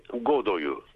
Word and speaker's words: Godot: Oyunun orijinali Godoy'u Beklerken. Godo tabii Godot gibi yazılıyor ama Godo diye okunuyor.